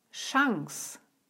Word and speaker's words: Chance: Noch häufiger wird CH am Anfang aber wie Sch ausgesprochen. Zum Beispiel bei den Worten Chance und Chef.